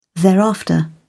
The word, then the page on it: thereafter: British English